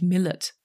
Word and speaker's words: Milat: Ja, Milat, weil die aus Kroatien stammen, wird ja, das so richtig ja. ausgesprochen. Aber die Ermittler und die Australier sagen natürlich Millet.